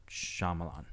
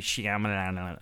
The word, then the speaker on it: Shyamalan: So for the listeners at home, that is how you properly pronounce his last name. There isn't a Shyamalan, it's just Shyamalan. M.